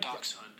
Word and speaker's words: dachshund: So it sounds like dachshund. – It sounds like dachshund, but it's spelled like that, and Americans just started spelling it like it sounds.